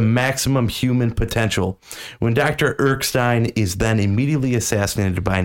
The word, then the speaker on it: Erskine: But everything changes when Dr. Erskine, how do you say that, Ben? – Erskine, there we go.